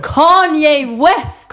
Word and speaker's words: Kanye: Ja, men Kino Weave. Han heter... Ka Kanye. Kanye West!